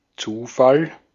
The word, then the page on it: accident: American English